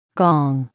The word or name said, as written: gong